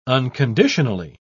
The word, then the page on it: unconditionally: British English